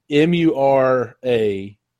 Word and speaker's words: Muramasa: I think is that what? Muramasa? – Yeah, I think that's how you say it. I could be wrong, but it's definitely, that is Muramasa.